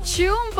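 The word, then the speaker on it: Chum (춤): Eso fue Chum por parte de Baby Monster. Así se pronuncia en coreano lo que es la palabra baile, bailar.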